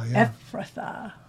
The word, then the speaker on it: Ephrathah: But you, Bethlehem, Ephrathah. Am I saying that right? – Ephrathah. – Oh, that's a tricky one with my THs.